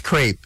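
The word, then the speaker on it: crêpe: The French say crepe. – Crepe. – Oh. Yeah. And when I was in the States, they were like, oh no, it's crepe.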